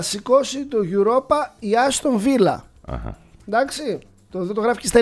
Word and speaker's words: Villa: Α, νόμισα, επειδή βλέπω το Μεντιλίμπαρ, νόμισα ότι είναι τέτοιο, Ιβηρικό. Και λέω τα δύο λάμδα προφέρονται γάμα. – Γι' αυτό επιμένω. – Λοιπόν, καλά κάνεις. – Φράιμπουργκ, Άστον Βίλα, στις 10 σήμερα.